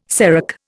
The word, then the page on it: serac: Indian